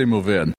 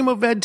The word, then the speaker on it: Mourvèdre: I say Mourvèd. – I say Mourvèd too. It just sounds, I don't know, I feel more sophisticated when I say Mourvèd. I do not need that last syllable.